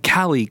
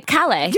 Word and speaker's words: Calle: Like, everywhere I go, they're like, oh my God, Callie, call, Kelly, yeah. Cal. And so it's so funny. – For our listeners, it's spelled C-A-L-L-E. – I'd say Calle. – Yeah, which is Spanish.